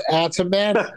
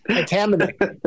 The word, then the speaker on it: Atamanuik: I just told you it's Atamanuik. Atamanuik.